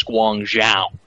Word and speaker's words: Guangzhou: I believe the pronunciation is Guangzhou. It's a Zhao sound. Zhao.